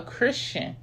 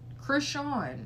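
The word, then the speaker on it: Chrisean: It's not Christian. It doesn't even look like it's spelled like Christian.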